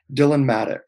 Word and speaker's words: Dylan Madoux: Um, Dylan Madoux.